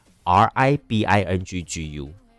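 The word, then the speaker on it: Living谷: 听完了日本的 YOASOBI 祝福之后， Living 谷是客语的双人组合， Living 专辑最近全新发行了，而且这个 Living 谷它虽然发音叫做 Living 谷，可是是日文的这个客厅翻过来的，所以是 RIBINGGU。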